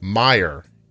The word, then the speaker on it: Meijer: Meiger. Here it is from Robin. Just a heads up, Meijer is pronounced Meyer, and it's a Michigan-based department store, groceries, clothing, and home goods, and a great pharmacy.